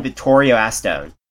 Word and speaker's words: Vittorio Astone: Where find Vittorio Astone? Am I saying the name right? – That is correct.